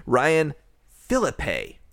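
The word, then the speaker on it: Ryan Phillippe: So what if I were to say the name Ryan Philippe? Okay. That is how he pronounces it.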